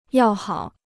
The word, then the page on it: 要好: yào hǎo